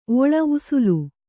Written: ಉಸುಲು

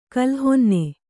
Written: ಕೋಲುಹೊನ್ನೆ

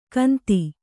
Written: ಗಂತಿ

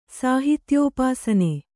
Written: ಸಾಹಿತ್ಯೋಪಾಸನೆ

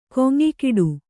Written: ಗುಂಯಿಗುಟ್ಟು

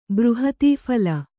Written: ಬೃಹತೀಫಲ

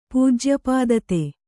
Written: ಪೂಜ್ಯಪಾದತೆ

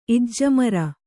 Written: ಇಜ್ಜಮರ

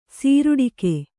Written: ಸೀರುಡಿಕೆ

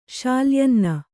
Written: ಶಾಲ್ಯಾನ್ನ

